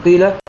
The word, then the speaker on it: قيل: والمراد بالإشمام هنا أن تشير بضم الشفتين عند نطق الحرف الأول من هذا الفعل، سواء حرف القاف من قيل أو الغين من غيظة، الجيم من جيئة، وكذلك في الكلمات التي في البيت الذي يليه تشير بضم الشفتين عند نطق الحرف الأول من هذه الكلمات، وتأتي بضمة بسيطة تعقبها مباشرة، يعقبها مباشرة حرف الياء. فنقول قيل، قيل، غيظة، جيئة، حيلة، سيق، سيئة، سيئت.